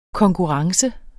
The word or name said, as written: konkurrence